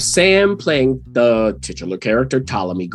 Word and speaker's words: Ptolemy: Ptolemy. P is silent.